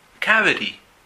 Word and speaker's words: cavity: Ahora, recuerden que en inglés total practicamos la pronunciación americana y muchas veces la T suena como una D. Cavity. Cavity. Por supuesto, también se podría decir cavity, que es más británico.